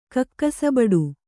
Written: ಕಕ್ಕಸಬಡು

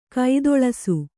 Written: ಕಾತಳಿಸು

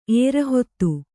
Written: ಏರಹೊತ್ತು

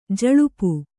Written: ಜುಲ್ಪಿ

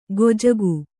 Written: ಗೊಜಗು

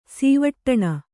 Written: ಸೀವಟ್ಟಣ

